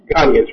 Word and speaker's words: Ghanaians: Maybe we can learn a whole bunch from the how do you say it, Ghanaians? – Ghanaians, right.